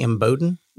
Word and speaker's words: Imboden: They own an office, United Country Scenic Rivers Realty in Imboden, Arkansas. Am I saying that right, Angie? Imboden. – You are. Way to go.